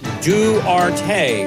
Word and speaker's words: Duarte: I'm told by one of our Spanish-speaking friends that dude's name is pronounced Duarte.